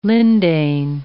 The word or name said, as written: lindane